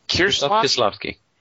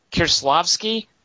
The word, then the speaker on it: Kieślowski: So Blue, of course, is Kieślowski. I'm not sure. You're Polish. How do you say his name? – Kieślowski. – Kieślowski. – Yeah.